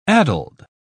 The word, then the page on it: addled: British English